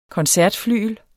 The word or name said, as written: koncertflygel